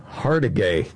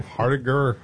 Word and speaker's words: Hargitay: Hargitay. Nice one. – Hardiger.